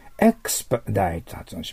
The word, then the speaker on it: expedite: はい、それ次は、その次は、はい、エクスパダイト発音します。エクスパダイト、これはペディに線引いてください。エクスパダイト、エクスペディってって書いてあるけど、エクスパダイトと発音しますが、ペディに線を引いてください。